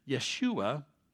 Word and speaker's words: Yeshua: Then Yeshua, that's how you pronounce Jesus in Hebrew.